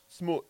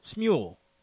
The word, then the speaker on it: Smule: Well, it's Mule with an S. – Smule. – Smule. That's how I've been saying it. – Smule.